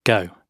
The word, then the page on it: go: British English